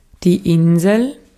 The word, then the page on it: island: [ˈaɪ.lənd]